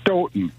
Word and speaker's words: Stoughton: You actually, the second time you said it right, Stoughton. They pronounce okay. it Stoughton. – Like Houghton, Michigan.